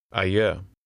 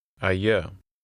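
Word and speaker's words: adjö: With this in mind, try to read number 8, where the D is silent. – Ayö. Ayö.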